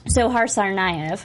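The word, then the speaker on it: Dzhokhar Tsarnaev: So now it's official help me pronounce his name. – Sohar Tsarnaev. – Thank you.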